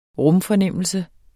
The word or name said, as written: rumfornemmelse